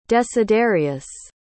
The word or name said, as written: desiderius